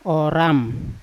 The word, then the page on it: oram: Yawa